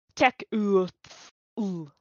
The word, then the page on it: Tekuthal: Phyrexian